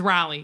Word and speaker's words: Raleigh: But uh, Sir Walter, is it R- Raleigh? I said it right because last time you made fun of me. – It was Raleigh. Yes, I'm. Well, I should say I'm assuming it's Raleigh because it's Raleigh, North Carolina.